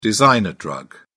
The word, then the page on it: designer drug: British English